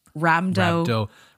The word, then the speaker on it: rhabdomyolysis: It's rhabdomyolysis.